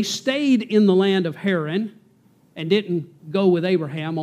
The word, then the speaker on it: Haran: Haran, H-A-R-A-N, or Haran, as people pronounce it nowadays.